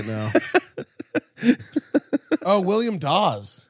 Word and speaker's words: Dawes: Dude, Dawes. – It rhymes with pause.